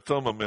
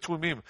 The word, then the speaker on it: Thummim: We say Urim and Thummim in Ohio, but no, it's Urim and Tumim.